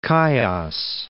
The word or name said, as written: Chios